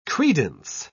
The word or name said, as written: credence